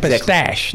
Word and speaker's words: pistache: A pistachio. – Pistachio. – What is a pistache, not a pistachio. – Okay, I said it wrong.